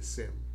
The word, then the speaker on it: Shem: It was Shem. The Hebrew pronunciation on they don't pronounce the H, so they would say Sem.